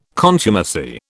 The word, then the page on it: contumacy: British English